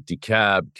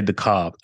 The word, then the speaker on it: DeKalb: Yeah, because some people say DeKalb. It's D-E-K-A-L-B. – K-A-L-B. – Yeah. – Okay. DeKalb. Okay. Yeah. I don't know. I'm just saying that there's an L missing when you pronounce it.